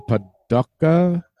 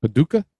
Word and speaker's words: Paducah: So he wrote on here, he said, so Ricochet was born in Illinois, but quickly moved to Paducah. Is that how you pronounce it? Paducah? – Sounds about right.